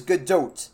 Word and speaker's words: Gadot: Gal Gadot, which, by the way, we were all saying it wrong in the Wonder Woman episode, is Gadot. I looked it up. I was pretty sure I knew that the T was pronounced.